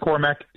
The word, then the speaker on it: Cormac: Just Cormac.